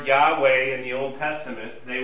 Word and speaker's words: Yahweh: When you translate I am, in Hebrew it's pronounced Yahweh, you translate that into Greek, you get ego eimi.